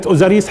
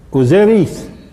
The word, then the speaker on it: أوزيريس: الآن بس جيد هون إن ننطق كلمة أوزاريس حتى تعرفوا شوية صغيرة، ننطق كلمة أوزاريس بدون تدخل يوناني، بدون يعني كيف كانت تلفظ أصلا من قبل المصريين. هل كانوا يقولوا أوزاريس؟ لا، كانوا يقولوا لحظة أوزيريس.